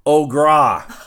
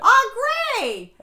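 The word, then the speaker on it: Au Gres: It's A-U space G-R-E-S. – Yeah, how did you say it? – Ogra. – How do you say it? – Ogre. How do you not know that? – Oh, I thought it was Ogra.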